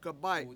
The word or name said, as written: gabAy7